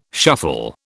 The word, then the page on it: shuffle: British English